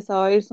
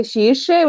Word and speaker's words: Saoirse: sí, cuando, cuando, lo, cuando lo pronuncian a vez como la, la piba esta, la actriz, la Saoirse Ronan, que tampoco se dice Saoirse su nombre, se dice que Shirche, una cosa así, a decir, ¿cómo, ¿cómo eso?